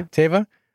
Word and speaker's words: Teva: So let's jump into, so we brought back Captain Carson Teva. Is that how you say his name? – Yeah. – Teva.